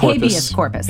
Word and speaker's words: habeas corpus: Habeas corpus. – There habeas we go. Corpus. Wrong emphasis on the wrong syllable.